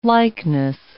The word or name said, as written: likeness